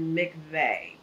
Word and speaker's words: mikveh: And so even that, I say, like, so that's okay, I love in Jewish culture, I think they pronounce it mikveh, but it's M-I-K-V-E-H.